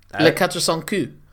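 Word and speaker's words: Les Quatre Cents Coups: Le Quatre Cent Coup.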